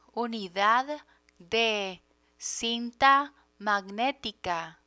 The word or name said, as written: unidad de cinta magnetica